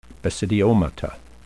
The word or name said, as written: basidiomata